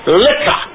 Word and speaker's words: liquor: Now, for 3 points, L-I-Q-U-O-R is any alcoholic drink. Would you pronounce this word lipo? A, B, lipuru, C, liquor, or D, lipo? – Liquor. – Liquor is the answer.